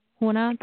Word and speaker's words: Huna: And the, I'm probably saying it incorrectly, the Huna? – Huna.